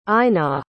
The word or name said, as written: einar